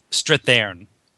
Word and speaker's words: Strathairn: Strathairn.